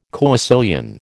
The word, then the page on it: coecilian: American English